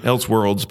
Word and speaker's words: Elseworlds: I think you might have been the guy that put me onto these, and I may say it incorrectly, Elseworld Tales. – Yeah, that's pretty much it. Elseworlds, but yeah.